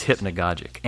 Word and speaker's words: hypnagogic: But it is hypnagogic and hypnagogia.